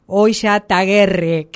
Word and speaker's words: Oillataguerre: Y para hablar de esto y de muchas más actividades del Club Larrazábal, está en línea Patricia, que siempre me equivoco el apellido, pero es Oya Taguerre. Creo que lo dije bien esta vez.